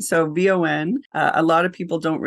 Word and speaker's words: VON: We internally pronounce it VON, not Vaughn, which is something that I hear a lot from people.